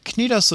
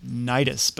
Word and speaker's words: Cnidos: You might also come across the pronunciation Nidus, but that's just plain silly.